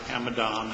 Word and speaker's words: Amidon: May it please the Court, my name is Robert Amidon. That's spelled A-M-I-D-O-N for the benefit of the record.